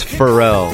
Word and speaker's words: Farrell: It's Farrell. – Farrell. Sorry. – Farrell. – Sorry, Farrell. – When you played this song, I was like's going to correct me or saying the name wrong. – Farrell. – So I just was waiting. I knew you'd say it. – Yeah. Sorry.